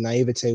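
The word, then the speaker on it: naïveté: Although I do feel as if that his age and his naivete, yeah, that's how you pronounce it, naivete was some of his downfall in some of these scenes.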